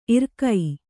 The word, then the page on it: ಇರಿಕೆ: irike